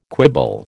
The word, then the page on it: quibble: American English